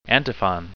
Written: antiphon

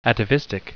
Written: atavistic

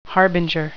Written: harbinger

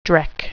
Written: dreck

